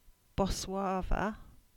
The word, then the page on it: Boswarva: English